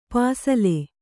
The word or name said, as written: ಪಸಲು